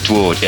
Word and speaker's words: Dwarde: Is it Dwarde? – This is Dwarde, yeah.